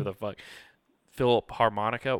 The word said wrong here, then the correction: Philharmonics: whatever the fuck it's called. How do you say it? – It was, let me go back here. It was, Philharmonics.